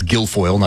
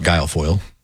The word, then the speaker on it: Guilfoyle: It's Guilfoyle, not Guilfoyle.